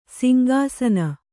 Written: ಸಿಂಗಾಸನ

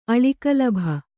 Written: ಅಳಿಕಲಭ

